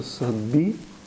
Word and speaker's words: съдби: Значи в Библията, като срещнете думата съдби, удряйте едно ударение на съдби.